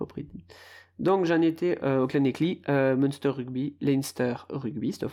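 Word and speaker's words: Llanelli: Et derrière, après, on a donc les Clanecli, les Scarlett de Clanecli, parce que ça s'écrit Lelani, mais ça se dit Clanecli.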